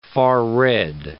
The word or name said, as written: far-red